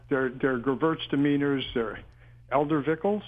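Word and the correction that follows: Edelzwicker: am I saying that right? – Eldervickels. – Edelsvicker? – Yeah, Edelsvicker.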